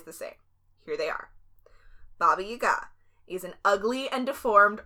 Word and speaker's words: Baba Yaga: And I'm saying Baba Yaga because that's the way the Russians pronounce it. Often in America, you'll hear Baba Yaga, which is, like, not wrong. It's just the American pronunciation.